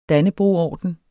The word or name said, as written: dannebrogorden